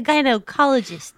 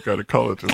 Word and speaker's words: gynecologist: Their feet are in stirrups, but then the hips and the legs, it's like, yeah, it's like a gynecologist type situation. – I've never heard the emphasis on the O in gynecologist.